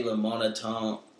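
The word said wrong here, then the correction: LaMontagne: La Montagne.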